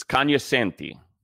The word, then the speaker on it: cognoscenti: It's cognoscenti. The G is silent.